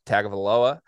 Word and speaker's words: Tagovailoa: Look, Maryland was a very good football team early in the season with Tua's brother, Tagovailoa, and he got hurt, and they were horrible for three weeks. – Good pronunciation right there.